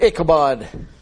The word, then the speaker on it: Ichabod: That word Ichabod, in the Hebrew, the B in that word, it's pronounced like a V, like vo.